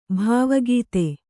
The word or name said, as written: ಭಾವುಕತೆ